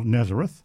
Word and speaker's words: Nazareth: It's spelt the same as the town in Israel, Nazareth. But the BBC guide, which we have in the studio here, says Nazare, and the AI, the worthy AI Google reference, says Nazarek.